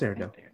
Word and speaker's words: Arendelle: Is it Arendelle? – Yeah, it's Arendelle. – Okay, I was like, did I say that right? – Yeah.